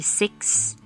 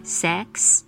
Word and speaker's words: six: 数字六是 six six。 S I X，学生很容易念成 sex sex 性别这个字，所以中间的母音要掌控好嘴型。